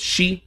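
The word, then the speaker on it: shi (四): And it's a lot easier to figure out why there's the fear of the number 4 because it's pronounced, in Japanese, it's pronounced shi, which is the same way that the word death is pronounced.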